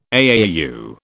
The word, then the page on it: AAU: British English